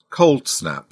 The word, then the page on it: cold snap: British English